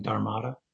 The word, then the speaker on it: dharmata: So when they say fundamental nature, it's a way of saying dharmata? – Yes, except they pronounce it correctly. Dharmata. – Dharmata. – Yeah, the last A has a little, it's a long A, which I don't know if that's coming, if that's shown here.